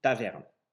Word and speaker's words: Taverne: Au fil du temps, le mot latin Tabernae a donné naissance au toponyme taverne. Contrairement à la prononciation habituelle, l'accentuation des toponymes similaires en allemand se fait sur la deuxième syllabe du nom, ce qui indique encore aujourd'hui l'origine romaine de notre localité.